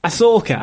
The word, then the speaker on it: Ahsoka: Ahsoka, I do apologize.